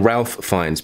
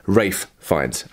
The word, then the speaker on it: Ralph Fiennes: Of course, I've been editing the video and I've just realised I've been saying Ralph Fiennes because that's how it's spelt. But that's not the pronunciation. The pronunciation is Ralph Fiennes, okay?